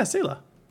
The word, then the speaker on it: Sela: Sela? Am I saying that right? Yeah, Sela.